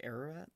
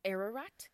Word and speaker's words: Ararat: So Mount Ararat. How do I say it? – I believe it's pronounced Ararat. – Ararat.